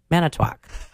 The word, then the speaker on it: Manitowoc: How do you say that? – Manitowoc.